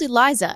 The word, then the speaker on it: Eliza: Yeah, she's called Eliza, and it's spelt in all the things as Elisa, so I'm a bit confused, but I think she's called Eliza. – Yeah, it's definitely Eliza. I remember them saying it.